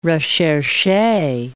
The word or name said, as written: recherche